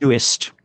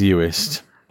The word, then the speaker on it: Uist: I think it's pronounced Uist, but... – Oh yeah, there's an I in that. Yep, it is Uist.